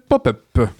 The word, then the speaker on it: Pop-Up: Bonjour, excusez-moi de vous déranger, je me présente, je m'appelle Pop-Up. Oh oui, Pop-Up, c'est mon nom, on prononce Pop comme dans Pop Music ou Population Mondiale, et Pup comme dans Peuplier ou un peu plus à l'ouest.